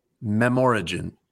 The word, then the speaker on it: Memorigen: I believe that this is pronounced Memorigen, like a combination of memory and origin.